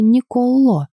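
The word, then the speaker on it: Николо: А имя капитана произносилось с ударением на последнюю гласную «Николло», также с ударением в конце.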